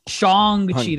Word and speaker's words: Shang-Chi: You better say his name right then. – Shang-Chi. That's right. They made a point.